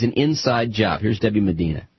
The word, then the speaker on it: Medina: Debbie Medina, is that my pronouncing her correct? Medina? – Yes, sir.